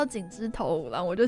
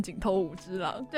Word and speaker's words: 井之头五郎: 讲到井之头五郎，我就想到我之前一直把它念成井头五之郎，对，我们上学期在试录的时候，我们然后我们那个 Rory 呢，他就一直把它念成井头五之郎，然后我就在那个中间的时候偷偷跟他说是井之头五郎哦。